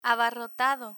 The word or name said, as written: abarrotado